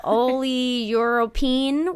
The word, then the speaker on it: oleuropein: It's a long O word. – Yeah, oleuropein, which signals the pancreas to secrete insulin.